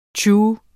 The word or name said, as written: tju